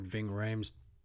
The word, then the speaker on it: Ving Rhames: Is that how you say his name? – Yeah, Ving Rhames.